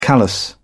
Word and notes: callus: British English

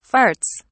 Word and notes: farts: British English

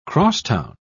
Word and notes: cross-town: British English

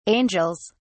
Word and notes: angels: British English

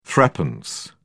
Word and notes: threepence: British English

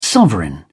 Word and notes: sovereign: British English

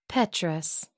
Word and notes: petrous: American English

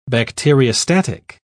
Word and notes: bacteriostatic: British English

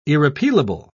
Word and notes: irrepealable: British English

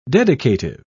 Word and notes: dedicative: British English

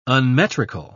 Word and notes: unmetrical: British English

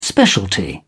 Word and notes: specialty: British English

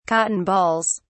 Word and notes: cotton balls: British English

